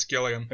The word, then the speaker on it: Gilliam: Is that how you say it, or is it Gilliam? – I think it's Gilliam. – Okay, that's what I thought too.